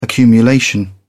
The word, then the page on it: accumulation: British English